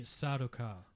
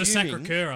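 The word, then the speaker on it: Sardaukar: They're called the Sadokar. – Sadokar. – Come on, team. – So I was calling them the Sacré-Cœur, I think. – You're our lore guy. – Close enough. – The Sadokar...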